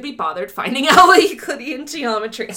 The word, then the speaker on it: Euclidean: I think I'm pronouncing that right because it's Euclid, I think. E-U-C-L-I-D. – Yeah. – So Euclidean, yep.